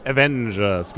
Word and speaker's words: Avengers: Avengers, genau.